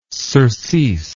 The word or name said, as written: surcease